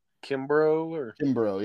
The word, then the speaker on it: Kimbrough: It was like Kimbrough. – Yeah, we got Charles Kimbrough, 86 years old, died of possibly natural causes, undisclosed, but.